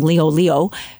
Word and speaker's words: Liholiho: They probably thought that King, I'm going to say it wrong, Liholiho.